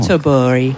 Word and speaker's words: Göteborg: Well, in English, the version is Gothenburg. But Göteborg, obviously, is how you say it in Swedish, but I gather that that's not the correct way to pronounce it.